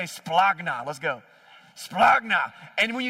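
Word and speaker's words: splagna (σπλάγχνα): But the Greek word is, the way we would pronounce it, is splagna.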